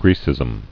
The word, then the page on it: Grecism: Gre·cism